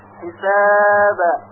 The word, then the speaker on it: حساب: مثلا به قلقله با در آخر کلمه حساب توجه کنید، حساب حساب، یعنی اگر به طرف کسره میل پیدا کنه حسابی غلط هست و همچنین به طرف کسره حساب این هم باز غلط هست، بلکه باید به طور ساکن تلفظ بشه حساب.